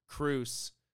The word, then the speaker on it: Kreese: Even I said, like, at the beginning, when you first see Kreese, damn it. I keep saying his name wrong, damn it. – Kreese. – Kreese.